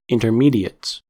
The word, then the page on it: intermediates: American English